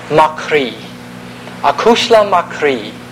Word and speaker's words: croidhe: So Cri, in Gaelic, is actually, just for fun, it's spelled C-R-O-I-D-H-E. The H makes the D disappear, yeah, or not be pronounced.